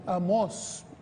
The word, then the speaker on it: Amós: No, Amós. Tiene un acento en la O.